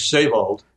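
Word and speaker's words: Seewald: Am I saying that right? Sewald, should I be saying, or Sewald? – Well, in German, it's Sewald. – Sewald, I see, yeah.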